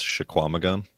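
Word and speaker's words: Chequamegon: I looked this up with native pronunciation. It's Shawamigan. At least they pronounced the Q.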